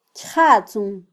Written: kyjatzun

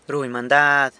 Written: runy mandad

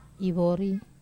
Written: vori

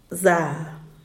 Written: za

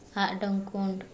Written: aʔɖoŋ ʤa:l

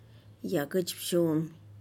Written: yaguchbixu'un

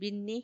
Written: binni

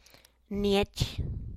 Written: niech

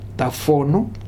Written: Tafonu

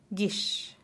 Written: giix

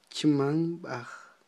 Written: chmamb'aj

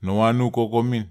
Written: nowanu koko min

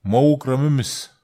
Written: mawuk ramemes

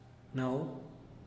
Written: nau

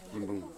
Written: yimbung

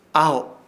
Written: AHO

